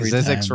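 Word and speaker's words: Zzyzx: Zix, the alphabetically last town name in the United States of America, is located in this state.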